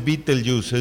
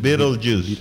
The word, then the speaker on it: Beetlejuice: Beetlejuice, no es Beetlejuice, es Beetlejuice. Ahí está, ya ves, para que vean.